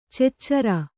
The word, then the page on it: ಚುಚುರು: cucuru; Kannada